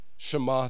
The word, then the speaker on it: Shema: Did I say it right? Shema, thank you.